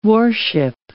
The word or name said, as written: warship